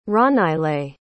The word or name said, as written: ronaele